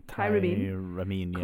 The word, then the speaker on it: tyramine: So the tyrene, is that how you say it? Tyramine? – Tyramine, causes the adrenal gland.